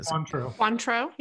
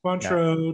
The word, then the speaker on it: Cointreau: Cointreau, yeah. – Cointreau, yeah.